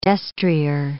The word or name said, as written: destrier